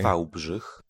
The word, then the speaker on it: Wałbrzych: In the town of Wałbrzych, Poland, and I got that pronounced from the online dictionary, Wałbrzych.